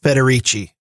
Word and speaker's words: Federighi: So this is Apple's senior vice president of software engineering, Craig Federighi. Federighi. – And he played, I had heard it pronounced Federici. – Federici, that could be.